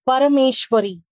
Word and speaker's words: Parameswari: How do you how do you exactly say it? – So um, it's Parameshwari.